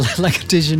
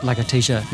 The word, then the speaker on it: Lagartija: It was just because I'd worked out how to pronounce Lagartija. – You were okay with Nick. – It's kind of like alopecia.